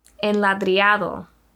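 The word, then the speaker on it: enladrillado: But the most popular part of this trail is, oh, this is the word I was practicing earlier, enladriado. Ooh, I think I said it right. – I think you did.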